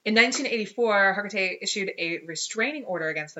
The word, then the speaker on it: Hargitay: Is that how you say it? – Hergate. Yeah. – Hegarty? – Hergate.